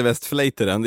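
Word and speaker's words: Westvleteren: För grejen är ju så här, att Westfleteren, det heter Westfleteren,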